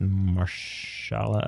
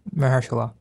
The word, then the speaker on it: Mahershala: And I'm going to butcher his name, but it's Marshala Ali. – Mahershala.